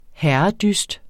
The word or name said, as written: herredyst